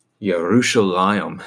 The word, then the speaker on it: Yerushalayim: It comes to be renamed Jerusalem, Yerushalayim, as it would be pronounced in the Hebrew, which has that word peace in it, Salem, Shalom.